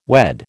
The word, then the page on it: wed: American English